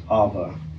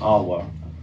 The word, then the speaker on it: Eve: Eve, more accurately pronounced Ava, or even Awa.